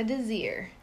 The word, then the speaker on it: Desir: Magda Desir. – How do you spell that? – M-A-G-D-A. – G-M-A-G-D-A. D-A. – Last name Desir. D-E-S. – D-E-S.